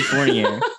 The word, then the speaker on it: Fournier: Fournier is spelled F-O-U-R-N-I-E-R. Fournier. It's French. F-O-U-R-N-I-E-R.